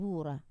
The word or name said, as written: vura